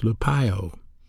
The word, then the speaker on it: lupeo: The original word for grieve is pronounced lupeo, L-U-P-E-O, and it's a verb.